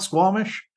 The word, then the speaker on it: Squamish: Am I even saying that right, Squamish? – You're saying it perfectly. It's like you live here.